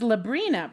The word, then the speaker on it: Librina: My name is Labrina, pronounced like the teenage witch, but with an L.